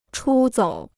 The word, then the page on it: 出走: chū zǒu